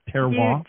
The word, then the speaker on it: terroir: Did I pronounce the title of the book correct? Is it Terroir? – You did. I could take lessons from you.